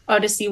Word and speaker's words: Odyzzey: Okay, so I mean, you pronounce it Odyssey, but it is spelled Odyssey, just to do a little bit of a standout.